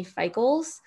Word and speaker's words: Feikls: So you can find me on Instagram at Emily Feikls.